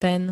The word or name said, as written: ten